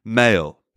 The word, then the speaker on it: Mayerl: Mayerl is spelled M-A-Y-E-R-L. So keep in mind, while I pronounce his name Mayerl, British people pronounce it Mail, because a British person would say, Billy Mayerl gave birth to a squirrel named Pearl, who was a girl, you know, something like that.